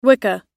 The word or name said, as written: wicca